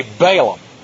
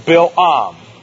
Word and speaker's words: Balaam: Moreover, we say Balaam, but this name is pronounced Bil-Am. Bil-Am.